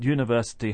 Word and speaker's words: university: Both those words begin with the letter U, but university has a y sound at the beginning, and uncle an a sound at the beginning.